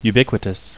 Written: ubiquitous

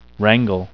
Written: wrangle

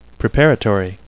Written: preparatory

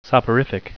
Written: soporific

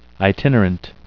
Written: itinerant

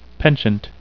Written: penchant

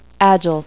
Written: agile

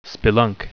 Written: spelunk

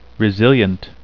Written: resilient

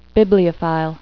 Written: bibliophile

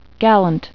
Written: gallant

